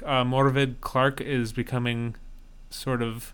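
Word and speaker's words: Morfydd: It's M-O-R-F-Y-D-D, which in that parlance is pronounced Morvid.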